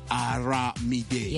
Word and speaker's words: Aramide: I knew it. I told you. Mamba, je vous ai dit, c'est comme Olomide. Mais seulement c'est Aramide. Yeah. Voilà, exactement. Moi, j'ai prononcé ça en français. I was pronouncing in French, Armide.